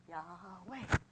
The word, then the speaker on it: Yahweh: Yahweh. Why do you walk two syllables? Yahweh.